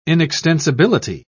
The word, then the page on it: inextensibility: British English